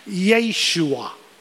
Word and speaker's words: Yeshua: The Hebrew word for Jesus, I believe most of you would know, is Yeshua.